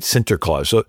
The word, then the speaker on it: Sinterklaas: now, St. Nicholas in the Dutch pronunciation is Sant Niklaus, right? Sinterklaas.